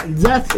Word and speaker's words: Zaz: Allora, Zaz, ho detto bene anche.